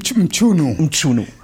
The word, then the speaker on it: Mchunu: Als Jugendlicher trifft, also erst macht er noch eine Tanzausbildung, also ein hochbegabter Tänzer, und dann trifft er Sifo Mchunu. Habe ich es richtig ausgesprochen? – Mchunu.